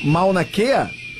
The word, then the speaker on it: Mauna Kea: Eu pronunciei corretamente o nome do furacão? Mauna Kea? – Perfeito.